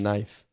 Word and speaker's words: knife: You don't pronounce the K in knife.